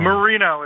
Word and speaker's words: Marino: Marino. It's like the Miami Dolphin, okay. But I'm M.O.